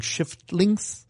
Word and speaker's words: Shiftlinks: There are a couple of game titles where you have to watch out that you pronounce them correctly, including Shiftlinks. Mind the F.